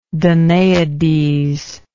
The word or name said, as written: danaidean